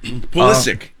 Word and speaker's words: Pulisic: I thought it was Pulisic or however you say his name. – Right. Pulisic.